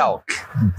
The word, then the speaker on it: Fealk: Felk.